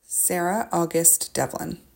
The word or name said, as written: Sarah August Devlin